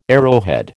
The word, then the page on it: arrowhead: American English